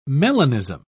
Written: melanism

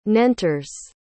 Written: nentres

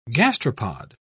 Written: gastropod

